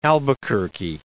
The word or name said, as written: Albuquerque, de